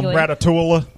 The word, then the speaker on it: Ratatouille: Well, Ratatouille. – I knew how to pronounce it from the get-go because that's the exact same thing as the city of Versailles in France.